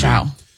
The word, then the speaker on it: Cao: Is this Chow, do you think? C-A-O? See if you can see how you pronounce that. The Secretary of the Navy. – Chow.